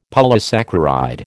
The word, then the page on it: polysaccharide: American English